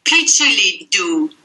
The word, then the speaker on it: picciliddu: In Sicilian, it's picciliddu.